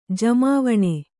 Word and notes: ಜಮಾವಣೆ: jamāvaṇe

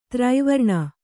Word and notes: ದುರ್ವ್ರಣ: durvraṇa

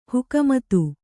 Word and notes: ಹುಕುಮತ್ತು: kannada

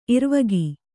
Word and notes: ಇರುವಿಕೆ: iruvike

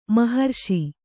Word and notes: ಮೋಹರಸ: mōha rasa

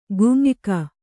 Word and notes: ಗುಂಯ್ಕ: gum`yka

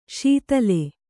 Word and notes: ಶಾತಲ: śatala

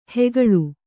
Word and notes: ಹುಗುಳು: kannada

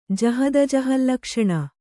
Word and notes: ಜಹದಜಹಲ್ಲಕ್ಷಣೆ: jahadajahallakṣaṇe

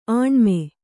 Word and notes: ಆಣ್ಮ: Kannada

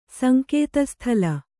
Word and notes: ಸಂಕೇತಸ್ಥಲ: Kannada

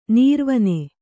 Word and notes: ನೀರ್ವನೆ: nīrvane

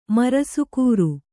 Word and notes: ಮರಸುಕೂರು: kannada

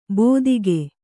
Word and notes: ಬೋದಿಗೆ: bōdige

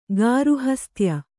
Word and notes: ಗಾರ್ಹಸ್ಥ್ಯ: gārhasthya

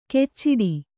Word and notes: ಕೆಚ್ಚಿಡಿ: kecciḍi

